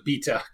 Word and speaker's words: B3ta: It's spelled B3TA. It's kind of a artifact of like pre-web 1.0 days of funny stuff being a mailing list. You guys heard of that? – Isn't that Beta? – I've never heard of it. – They pronounce it Beta because it's a British newsletter.